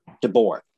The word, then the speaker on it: DeBoer: DeBoer.